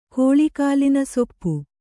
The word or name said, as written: ಕೋಳಿಕಾಲಿನಸೊಪ್ಪು